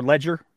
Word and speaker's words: Leger: Leger released a new poll, or Ledger, if you want to pronounce it that way.